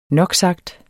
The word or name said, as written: noksagt